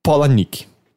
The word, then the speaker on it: Palahniuk: É tipo, eu não vou lembrar exatamente, mas tem no vídeo, é tipo Chuck Polanik.